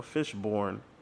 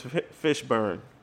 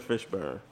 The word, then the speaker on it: Fishburne: Was it always Fishburne or Fishborn? I think it's Fishburne. Yeah, it's always been Fishburne.